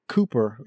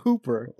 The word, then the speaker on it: Hooper: Combine, like one of the things that Cooper, is it Cooper or Boomer? I'm not even sure if I'm saying his name right. – Hooper.